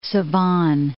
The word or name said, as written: Sevan